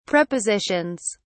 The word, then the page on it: prepositions: British English